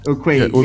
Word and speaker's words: Urquidy: I can't say his name. Urquidy. It's Urquidy. – Yeah, Urquidy.